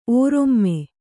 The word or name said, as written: ಒರ್ಮೆ